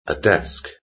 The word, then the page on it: a desk: British English